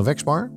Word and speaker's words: Wexbar: Sophie van den Haak eh, van de Wexbar, zeg ik dat goed? – Ja, heel nou, goed.